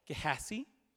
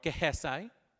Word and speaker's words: Gehazi: character called Gehazi or Gehazi. Some people have different pronunciations.